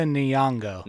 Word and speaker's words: Nyong'o: Matt as Lupita Nyong'o.